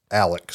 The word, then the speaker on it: Alec: And that's also why you've probably seen the main character's name written as Alex, but down there they pronounce it Alec.